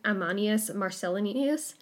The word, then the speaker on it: Ammianus Marcellinus: There was a Greco-Roman historian, Ammianus Marcellinus. – Nice job. – And he was... – That's one of your better pronunciations, I'd say. – Yeah, that was great.